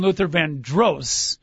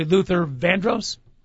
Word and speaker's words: Luther Vandross: I called him Luther Vandross. It's actually Luther Vandross? – I would have to say that's a better pronunciation, yes.